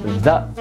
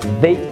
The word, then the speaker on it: the: 定冠词 the 有两种发音，一个是 the，还有一个是 they。怎么选择呢？跟刚才讲的选择不定冠词的方法一样，我们要看的是这个单词后面的词，它的第一个发音是元音还是辅音。